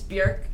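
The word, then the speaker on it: Björk: If he gets Elliott Smith, I get Bjork, so it works out. – It's Bjork, actually. It rhymes with jerk.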